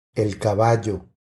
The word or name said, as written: el caballo